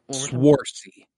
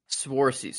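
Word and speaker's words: Saoirse: I always pronounce her first name wrong. Swarcy. And I'm afraid to say it. Sort. Huh? – Swarcy. – Swarcy. So like a W in there.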